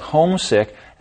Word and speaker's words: homesick: Listen to where the stress is. Johnny felt homesick after he moved out of the house. The stress is on the first syllable, homesick. – But Johnny is also homesick with the flu. In this case, the stress is on the word sick.